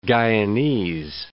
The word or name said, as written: Guyanese